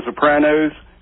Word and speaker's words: Sopranos: Hey, I want to go back a couple weeks ago on The Sopranos. Do you remember how Tony... – Thank you, incidentally, for pronouncing it the right way.